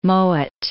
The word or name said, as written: Mowat